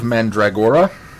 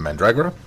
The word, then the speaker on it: Mandragora: Uh, the following week, we're going to do Mask of Mandragora, which I still think I'm mispronouncing that one. And um, I think you're emphasizing "or" too much, but you're got the pronunciation close.